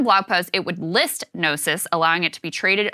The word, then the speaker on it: Gnosis: G-N-O-S-I-S. Gnosis coins? – Yeah, that's Gnosis.